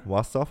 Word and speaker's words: Warsaw: Warsaw, aber spricht man so auf Englisch aus? – Yeah, I, I have been in Warsaw. Warsaw. – Warsaw. Warsaw. Sagen Warsaw. – Warsaw. Ja, ich glaube schon.